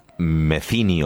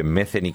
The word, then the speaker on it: Metheny: Se escribe Metheny con TH. Se pronuncia, aprendí de grande, Metheny. Le dije mucho tiempo Metheny, como todo el mundo.